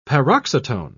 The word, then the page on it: paroxytone: British English